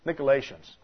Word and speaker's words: Nicolaitans: The Greek pronunciation is Nicolaites, something like that. Nicolaitans, that's the way we say it.